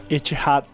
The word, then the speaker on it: Etihad: Como é que fala? – Etihad.